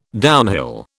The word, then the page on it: downhill: British English